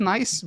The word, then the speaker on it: NAIS: It's pronounced NICE, but it's written in N-A-I-S.